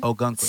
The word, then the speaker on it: Ogunquit: Algonquin? – Ogunquit. – Stars, oh. Nigga.